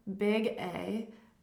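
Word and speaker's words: Big É: I am Big A, but E with an accent, which is pronounced A. So it's like Big A.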